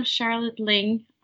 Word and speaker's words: Charlotte Lyng: Yes, and I'm Charlotte Lyng.